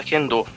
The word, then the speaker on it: Kendo: Aqui no caso tem, aqui no caso é Kendo a pronúncia.